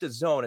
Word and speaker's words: DAZN: He sits as global marketing director for, it's pronounced DAZN, it's spelled D-A-Z-N, a London-based sports streaming service already in multiple countries around the world.